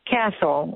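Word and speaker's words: CASEL: I know that you founded an organization called, is it pronounced Cassell or Castle? – Castle, which is stands for Collaborative for Academic, Social, and Emotional Learning.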